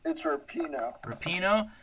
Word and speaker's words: Rapinoe: Is it, how do you say her last name? – It's Rapinoe.